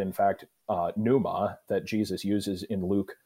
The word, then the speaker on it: pneuma: Because if the word was pneuma, right, or pneuma, if you like your silent P on the front, right, then we're talking about spirit.